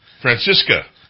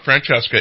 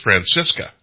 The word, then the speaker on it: Francisca: Francisca, by the way, it's not Francesca, it's Francisca. – Okay. – Right? – Well, you say so. – That's the way she, that's the way she pronounces it, Joanna Francisca.